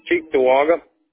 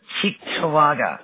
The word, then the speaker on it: Cheektowaga: C-H-E-E. – Wait. C-H-E-D. – C-H-E-E-K-T-O-W-A-G-A. – Wow. Cheektowaga. Is that how you pronounce it? – Yep.